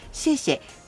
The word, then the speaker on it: 谢谢: はい、これも結構ニーハオ、ツァイチェンと並んで有名な言葉と思いますが、シェシェ、感謝の謝を 2 つ合わせてこう言うんですよね。ちょっと発音の練習してみますよ。 – シェシェシェシェ。 – はい、シェシェと、まあ、笑顔を添えてお礼の言葉言ってみるといいと思います。このシェシェと言われたら、まあ、今までニーハオと言われたらニーハオ、ツァイチェンと言われたらツァイチェンだったんですけど、シェシェと言われたら返す言葉はいろいろですよね。